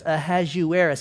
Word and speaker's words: Ahasuerus: I have to write it out in my notes, Ahasuerus, because it's hard to say, and you read it, not sure how to do it, but that's how you pronounce it.